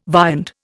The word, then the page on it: viand: Indian